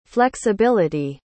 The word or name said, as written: flexibilty